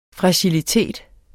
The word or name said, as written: fragilitet